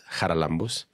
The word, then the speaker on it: Charalambous: I was emceeing, and being from a Greek family, you have to pronounce it Charalambous. And my brother and his wife come inside, and I say, please stand and welcome Mr. and Mrs. Charalambous.